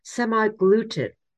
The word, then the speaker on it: semaglutide: They're called semaglutide, S-E-M-A-G-L-U-T-I-D-E, semaglutide. And the brand names are R-E-G-O-V-Y, Rigovi, or Ozempic.